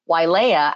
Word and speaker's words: Wailea: And I find it very appropriate in outrigger canoe paddling for myself that Wailea actually means waters of Leia. So Y, or often pronounced the W as a V in the Hawaiian language, is Vai.